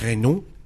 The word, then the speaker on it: Renault: Pero los estamos pronunciando muy mal los franceses, güey. Porque es Renault.